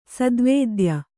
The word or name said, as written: ಸದ್ವೇದ್ಯ